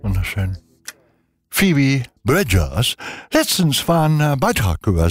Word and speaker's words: Bridgers: also Bridges, nein, Bridgers, so Phoebe Bridgers, so heißt sie. Wir wollen,